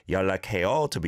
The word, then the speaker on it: 연락해요: Again, yeah, trying to overpronounce the 연락, 연락. It's not like that. 연락, 연락. The neon blends into the leal of lak and basically is a leal. So 연락해, 연락해, 연락해요 to be more polite.